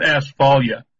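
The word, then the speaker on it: Asfaleia: It's pronounced asphalia. Like I said, that means safety in Greek.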